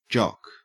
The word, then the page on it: jock: Australian English